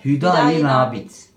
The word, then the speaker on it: Hüdayi Nabit: Nasıl telaffuz ediyor? Hüda Hüdayi Nabit.